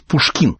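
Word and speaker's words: Pouchkine: Только правильно сказать Пушкин, потому что она пишется по-французски.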